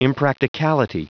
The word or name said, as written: impracticality